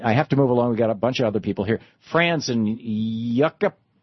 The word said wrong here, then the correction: Yucaipa: How do you say that? Say it again. – Yucaipa, California. Yucaipa. Okay.